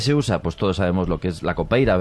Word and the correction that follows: capoeira: ¿verdad? – Capoeira. – Qué mal pronuncio últimamente.